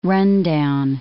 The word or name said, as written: rundown